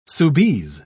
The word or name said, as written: soubise